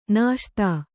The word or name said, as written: ನಾಶ್ತಾ